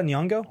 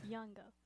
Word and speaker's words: Nyong'o: Lupita Nyong'o. – Nyong'o. – There's a Y. Unless I misspelled it and I'm screwing you up. What'd you say? – Nyong'o. – Yeah, Nyong'o. – Nyong'o.